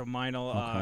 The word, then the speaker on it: Meinl: Yeah, so Meinl, okay.